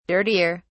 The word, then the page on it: dirtier: British English